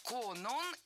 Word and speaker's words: Конон: Конон – это имя, и ударение в этом слове падает на второй слог.